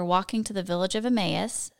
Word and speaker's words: Emmaus: Or how do you say it? – Emmaus.